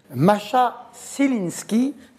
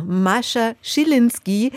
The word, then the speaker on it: Mascha Schilinski: Thierry Frémaux, das hat man auf der Pressekonferenz gemerkt, muss noch ein bisschen an der Aussprache ihres Namens arbeiten. – Masha Szelinski. J'ai bon, parce que c'est une cinéaste allemande. Son titre est en anglais, Sound of Falling. – Also Mascha Schilinski, die wird da sein mit ihrem zweiten Spielfilm In die Sonne schauen.